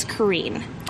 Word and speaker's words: Karreen: And Andy looks at me, and to add to the story, my full name's not Carrie, it's Kareen. And it's spelled. – Which I did not know that. – Yeah, it's spelled K-A-R-R-E-E-N.